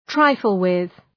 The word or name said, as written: trifle with